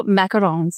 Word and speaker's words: macarons: So you had something in your about me about, I'm going to say it wrong, Macrons? – Oh, macarons. Yep.